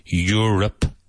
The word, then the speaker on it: Europe: Europe is a continent. Now the first syllable, your. Like tuyo, your. Europe. Europe is a continent.